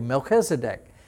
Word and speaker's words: Melchizedek: His name in Hebrew, now in English we call it Melchizedek. Oh, it's Melchizedek. And even in English we think, oh, that was so good. I learned how to pronounce that properly, Melchizedek.